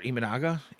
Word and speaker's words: Imanaga: Or Imanaga. I'm sorry, I said that wrong. I caught myself. I was like, wait a minute, that didn't sound right.